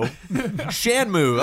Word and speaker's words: Shenmue: It'd be great to spell it like two words, like S-H-A-N-M-O-O. Shenmue. That looks good.